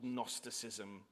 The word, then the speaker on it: Gnosticism: There's an intellectual threat in and around the community, which we assume based on the things that's written, this thing called Gnosticism, spelt with a silent G, though very much the root of our word knowledge, which has a silent K.